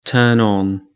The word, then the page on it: turn on: British English